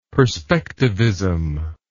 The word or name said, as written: perspectivism